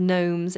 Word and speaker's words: gnome: But a gnome, it's a very strange word. It's spelt with a G-N. It's a silent G, which is very odd.